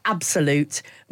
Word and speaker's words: absolute: There's the b and the p, right? Are very similar sounds, but they depend on the vowel that comes next. And the vowel sound that comes next, there's an S before the vowel sound. So that means the b will always sound in certain English dialects like a p, because that follows on more easily than... So although it's spelt absolute, most English speakers would say absolute.